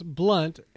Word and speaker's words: Blount: I did want to compliment the nice runs from LeGarrette Blount, who I understand is the pride of Oregon. – Actually, it's pronounced Blunt, and he unironically has been suspended at one time for being high.